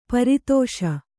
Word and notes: ಪ್ರಾದೇಶ: prādēśa

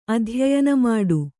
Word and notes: ಅಧ್ಯಯನಮಾಡು: adhyayanamāḍu

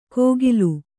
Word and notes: ಕೋಗಿಲು: kōgilu

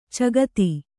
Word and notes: ಚಕೋತ್ತು: kannada

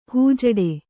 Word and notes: ಹೂಜಡೆ: Kannada; hūjaḍe

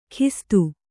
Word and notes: ಖಿಸ್ತು: khistu

